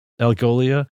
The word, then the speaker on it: Algolia: So I was really impressed with this Algolia. Is that how you pronounce it? – Algolia.